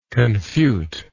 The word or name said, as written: confuter